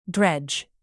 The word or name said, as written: dredge